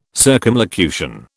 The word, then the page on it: circumlocution: British English